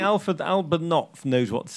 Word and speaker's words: Knopf: This is the people at Knopf. – Yep. – Rog, I believe is yep. – how it's pronounced. – Yep. – They've asked us, Knopf.